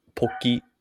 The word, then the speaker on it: Pocky: Well, in Japanese, it would be Pokey. Yeah. And in English, I think we say Pocky.